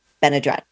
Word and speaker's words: Benadrete: Um, today I have sisters Joanna and Anissa Benadrete.